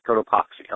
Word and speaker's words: Cotopaxi: Cotopaxi. – Cotopaxi.